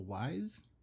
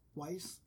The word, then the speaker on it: Weisz: Wise. Weisz. – Weisz.